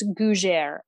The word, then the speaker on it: gougère: I think I've been really gouging this word. Okay, I believe it's gougère.